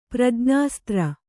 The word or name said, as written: ಪ್ರಜ್ಞಾಸ್ತ್ರ